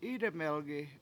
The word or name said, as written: Idemelgi7